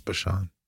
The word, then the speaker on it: Bashan: A mountain of God is the mountain of Bashan. Sometimes pronounced Bashan.